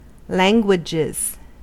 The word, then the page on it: languages: American English